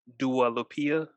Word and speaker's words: Dua Lipa: You got a top female artist, Adele, Doja Cat, Taylor Swift, Dua Lipa. I guess that's how you pronounce the chick name. – That sounds good.